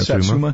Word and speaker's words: Satsuma: Satsuma. Isn't that how you say it? – Satsuma? Yeah.